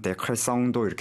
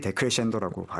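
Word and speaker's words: decrescendo: 그래서 마치 철자만 보면은 데크레성도 이렇게 발음할 것 같은데 정확하게 데크레션도라고 발음을 이탈리아계답게 합니다.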